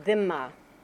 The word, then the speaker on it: dhimma: There was the notion of the dhimma. The Arabic letter is a dha, so it's sort of pronounced dhimma, or the plural is dhimmi.